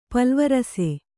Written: ಪಲ್ವರಸೆ

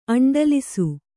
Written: ಅಂಡಲಿಸು

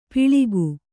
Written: ಪಿಳಿಕು

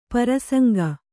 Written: ಪ್ರಸಂಗಿ

